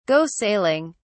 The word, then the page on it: go sailing: British English